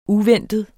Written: uventet

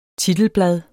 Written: titelblad

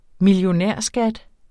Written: millionærskat

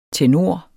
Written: Tenorer

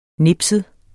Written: nipset

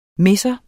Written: misser

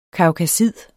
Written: kaukasid

